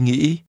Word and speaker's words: nghĩ: Tôi nghĩ, tôi đi hôm nay. – I think. – Hãy nghe và nhắc lại. – Tôi nghĩ. Nghĩ. Nghĩ. – Think is pronounced with a high rising broken tone.